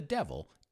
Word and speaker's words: devil: The devil is evil. Even though the spelling is similar, I should say the same, except for the D, they're not pronounced the same.